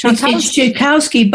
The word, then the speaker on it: Schakowsky: It's Schakowsky, but it's got a Y at the end of it.